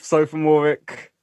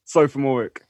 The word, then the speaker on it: Sophomooreik: Sophomooreik. – How do you say it? – Sophomooreik.